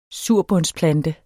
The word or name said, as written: surbundsplante